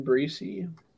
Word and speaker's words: Bracy: The Vikings are going to be all in on Brian Breesy from Clemson. They need some D-line help, so. – All right. Brian Bracy. That's how you spell it. Okay. Brian with a Y.